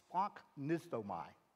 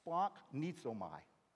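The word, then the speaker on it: Splanknizomai (σπλαγχνίζομαι): If you like Star Trek and you're into Klingons, sounds like a Klingon word. Splanknizomai. Can you say it with me? Splanknizomai.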